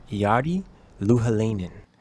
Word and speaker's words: Jari Louhelainen: We have a special guest, a doctor of molecular biology, Dr. Jari Louhelainen.